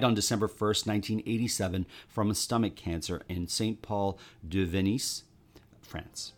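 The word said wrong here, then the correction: Saint-Paul-de-Vence: Is that how you say it? Venise? – St. Paul de Vence. – Oh, St. Paul de Vence, France.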